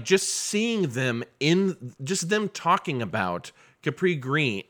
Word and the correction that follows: Cabrini Green: Wait, did I just say that right? – Cabrini Green. – Green, yeah, thank you.